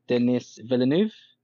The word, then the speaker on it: Denis Villeneuve: was Dune by Denis Villeneuve. That's how you pronounce his name.